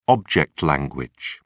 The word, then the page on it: object language: British English